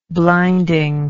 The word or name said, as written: blinding